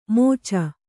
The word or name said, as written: ಮೋಚ